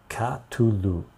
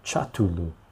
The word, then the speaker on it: Cthulhu: Cthulhu. Cthulhu. Aha, okay, that's how you pronounce it. Cthulhu. actually probably Chatulu.